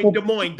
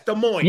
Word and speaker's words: Des Moines: Des Moines. Don't say the S. Des Moines.